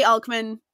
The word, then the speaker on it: Alcman: as usual, Greek pronunciation is fake. Yeah. I say Alcman. I think that's fine.